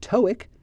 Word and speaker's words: TOEIC: And TOEIC stands for the Test of English for International Communication, T-O-E-I-C, TOEIC. So those are two examples of acronyms. It's a sequence of initials that we pronounce like a word, TOEFL and TOEIC.